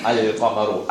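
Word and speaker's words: Al-Qamaru (القمر): Al-Qamaru. So you're pronouncing the lam. Al-Qamaru.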